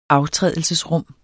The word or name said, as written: aftrædelsesrum